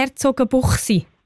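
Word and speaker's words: Herzogenbuchsee: Habe ich es eigentlich richtig gesagt, Herzogenbuchsi? – Ist genau, gut so? Ja, das ist so.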